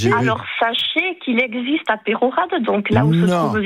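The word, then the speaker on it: Peyrehorade: Et je vous remercie de bien prononcer le nom de Peyrorade. On dit dans la région. On prononce le E et le Y, et je suis bien placé pour le savoir.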